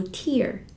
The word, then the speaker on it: tear: 这个词作为名词的时候读 tear，是眼泪的意思，眼泪、泪珠。而它做动词的时候读作 tear，tear。